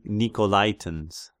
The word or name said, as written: Nikolaitans